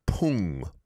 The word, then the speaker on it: 풍: The second syllable of storm, pung, pung, it's an iung. So make sure that iung sound, ung, is pronounced there.